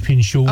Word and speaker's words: Pinchot: Bronson Pinchot, I think is how you say it. – Pinchot. – Oh, there we go.